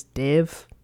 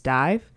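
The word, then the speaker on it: DIIV: I was listening to it earlier this year, and it's actually how I found out I was mispronouncing the name of that band. I'd been pronouncing it as Div, but it's Dive.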